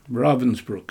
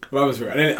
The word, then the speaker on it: Ravensbrück: In, I'm forgetting the name of the concentration camp, Ravensbrück. – Ravensbrück.